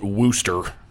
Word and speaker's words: Worcester: And I believe they call it Worcester, Massachusetts, even though it's spelled Worcester.